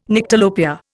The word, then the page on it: Nyctalopia: Indian